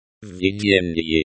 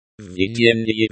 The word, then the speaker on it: Введение: The v at the beginning of the word vidyennye means in, and vidyennye means leading. The ye at the end of the word vidyennye is pronounced like the English word ye in yes. The stress in the word vidyennye is on the first syllable.